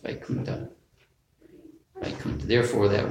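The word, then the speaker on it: Vaikuntha (वैकुण्ठ): Some words we we pronounce the th like it's supposed to be in Sanskrit. Ta, Vaikuntha, and Thakur.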